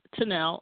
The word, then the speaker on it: Tunnell: I'm at Denise Tunnell on all social medias.